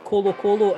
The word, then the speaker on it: Colo Colo: Did you like my pronunciation of Colo Colo and Universidad Católica? – Very good.